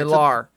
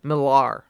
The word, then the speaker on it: Millar: Right, Millar. Sorry, Millar. – Oh, is that true? – Yeah, it's like Mark Millar. – Oh, does he actually pronounce it that way? – Millar. Yeah. – Okay, all right. Because I do know. A person... – I know I'm bad with it. – No, but I know a person named spelled that way, but pronounces it Miller like that.